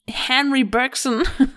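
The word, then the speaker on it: Henri Bergson: or if you want to pronounce him English, Henry Bergson.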